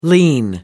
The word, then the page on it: lien: American English